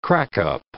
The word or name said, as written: crack-up